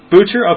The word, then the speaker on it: Boucher: how do you say his name? – I think it's Boucher. Okay. – Boucher, Boucher, one of them. – Boucher, that's it.